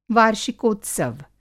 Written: वार्षिकोत्सव

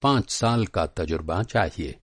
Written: तजुर्बा

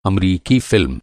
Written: अम

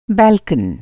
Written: बाल्कन

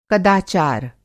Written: कदाचार

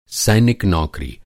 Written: नौकरी

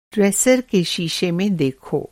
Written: ड्रेसर

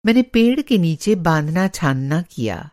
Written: बाँधना-छाँदना

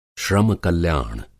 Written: श्रम-कल्याण

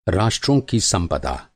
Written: संपदा

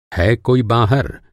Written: है

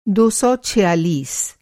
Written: दो सौ छियालीस

